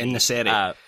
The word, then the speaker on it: En-Nesyri: En-Nesyri.